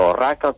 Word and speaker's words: Orraca: Os nomes das dúas aldeas oficializáronse con O, tanto Orraca como Outeiro de Orraca. A tendencia é pronunciálo con U, pero na documentación histórica nos vímolo sempre escrito con O, e por iso se mantivo con O.